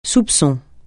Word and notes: soupçon: francais

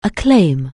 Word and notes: acclaim: anglais

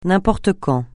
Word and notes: n'importe quand: francais

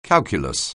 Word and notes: calculus: anglais

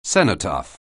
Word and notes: cenotaph: anglais

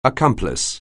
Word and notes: accomplice: anglais

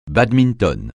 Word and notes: badminton: francais

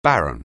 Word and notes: barren: anglais

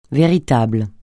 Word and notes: véritable: francais

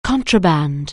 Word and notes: contraband: anglais

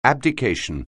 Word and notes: abdication: anglais

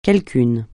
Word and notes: quelqu'un: francais

